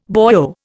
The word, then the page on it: boyo: Indian